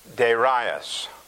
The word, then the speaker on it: Darius: Or I guess if I say that right, it's Darius. King Darius. Get the long A and the long I in there, like it shows.